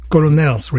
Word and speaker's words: colonel: Alors, je me sers la, la Colonel Retreats, Retreat, sorry, Colonel's Retreat Lager alors, euh, colonel, c'est un mot en anglais qui est assez euh, bizarre parce qu'il se, il se prononce colonel.